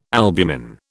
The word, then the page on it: albumin: British English